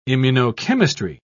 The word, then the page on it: immunochemistry: British English